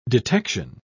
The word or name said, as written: detection